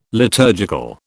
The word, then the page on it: liturgical: British English